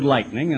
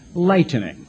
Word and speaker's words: lightening: So the key is you look up the word lightning in a Strong's Concordance, and sure enough, it means lightening. Pronounce it that way. Not lightning, like a thunderbolt, lightning bolt, but lightening.